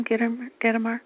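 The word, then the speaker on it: Gitomer: Is that how you say his name, Gittimer? – You got it.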